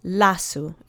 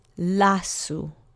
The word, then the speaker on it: lasu: And the last word is lasu. And this is object form again. And it means forest or woods. And the accent is on the penultimate syllable. Let's say it together three times. Lasu. Lasu.